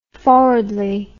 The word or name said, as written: forwardly